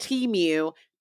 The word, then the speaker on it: Temu: And that is the brand Temu. That's how we pronounce it.